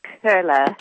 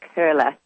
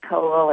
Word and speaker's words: Koehle: It's pronounced Kurla. You have to be a good German to know that. – Kurla? – My husband is from Germany, and in German they put a umlaut, a little dot dot over the O, and that makes the O sound like a U-R. So you get Kurla. – So Orlene Kurla. – And it's spelled K-O-E-H-L-E.